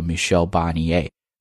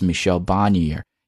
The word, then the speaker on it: Michel Barnier: Michel Barnier 啊，这个人呢就是现在的欧盟首席谈判代表 Chief Negotiator，但呢这个人的名字的发音是按法语的方式来发的，叫 Michel Barnier。 If you're going to pronounce his name according to English pronunciation, that's Michel Barnier. You can use both pronunciations 啊。